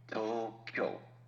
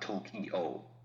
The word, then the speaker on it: Tokyo: Tokyo, two syllables, not Tokyo. That'd be three. Because in Japanese phonology, kyo is an acceptable syllable, where in English it is not.